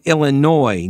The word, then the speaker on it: Illinois: It's pronounced Illinois. Oh, no, Illinois, no S.